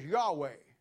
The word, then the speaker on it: Yahweh: The Tetragrammaton was the holy name of God that the Jews in the Old Testament saw it but didn't know how to pronounce it. The closest we get to it is Yahweh.